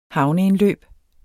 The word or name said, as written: havneindløb